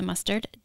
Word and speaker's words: Mustard: My name is Lisa Mustard, just like you put on a hot dog.